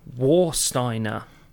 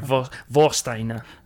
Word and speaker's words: Warsteiner: So, yeah, this week, the tipple we'll start off with, and it is Warsteiner. Do you know much about this? – If you pronounce it correctly, possibly. – Oh, Warsteiner. Sorry, Warsteiner.